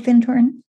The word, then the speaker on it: Findhorn: Do you know of Findhorn? – No. – Yeah, you got to look it up. – How do you spell that? – F-I-N-D, like find, horn, yep. H-O-N, one word, Findhorn, in Northern Scotland.